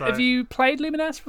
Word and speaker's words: Lumines: Lumines Puzzle Fusion. How do you pronounce it? – It's I can't even say it in the first instance. Lumines is, I believe, how it is pronounced.